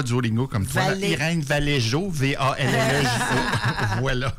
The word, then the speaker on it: Irene Vallejo: Voilà, donc je te laisse prononcer le nom de l'autrice avec l'accent espagnol. – Merci, Valérie, de me mettre sous le projecteur. Alors, c'est Irene Vallejo. Oui, non? – Irene Vallejo.